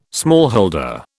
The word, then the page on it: smallholder: British English